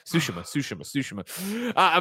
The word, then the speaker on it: Tsushima: I keep changing the pronunciation because I go back to what's wrong and try to get back to what's right. Tsushima.